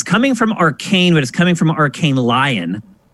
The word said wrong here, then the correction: Lyon: Arkane Lyon. – Lyon, right. Yeah, I did mispronounce that.